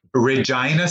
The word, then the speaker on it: Regina: Regina, Saskatchewan. That's exactly how it's pronounced.